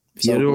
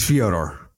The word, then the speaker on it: Fyodor: Fyodor won in overtime. – It's Fyodor. Say it right.